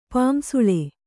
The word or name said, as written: ಪಾಂಸುಳ